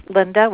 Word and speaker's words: Lynda: It is Linda, which is spelled L-Y-N-D-A.